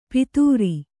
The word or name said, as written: ಪಿತೂರಿ